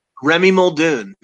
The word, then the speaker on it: Remi Muldoon: Because I know you illustrate the life of the biblical life of Remy Muldoon. Am I saying that right? – Remy Muldoon. No problem.